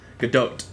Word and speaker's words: Gadot: Because I've heard her pronounce it with the T at the end. Gadot. She's pronounced the T when she says it, but when other people say it, they never put the T in.